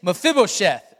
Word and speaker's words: Mephibosheth: The name is Mephibosheth.